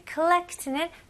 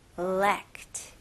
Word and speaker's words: collect: Collect. Collect. 여기서는 O 발음이지만 호 이런 느낌 아니고 collect. 여기는 거의 A 느낌으로 하셔도 돼요. 예를 들어서 누구한테 call 할 때 collect. Collect. A하고 U 사이인 것 같아요.